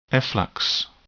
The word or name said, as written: efflux